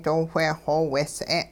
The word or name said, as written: doo whe xoweeys'e'n